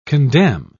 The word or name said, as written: condemn